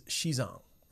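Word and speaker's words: Xizang (西藏): So we were getting real deep into it, and in Mandarin, Tibet is Xizang. Right? That's how you say it in Mandarin.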